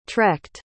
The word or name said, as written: trekked